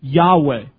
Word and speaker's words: Yahweh: Yahweh is probably the best we can do to pronounce it.